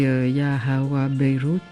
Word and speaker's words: Ya Hawa Beirut: Sur cet album, vous reprenez un titre, alors je vais le prononcer à la française et vous me corrigerez, c'est Ya Hawa Beirut, ça va ? – Bien, pas de correction.